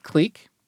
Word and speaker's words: clique: So the interesting thing is that in the UK, they pronounce it click. And very commonly in academic contexts, especially this is a word that comes up in graph theory, it's pronounced click as well.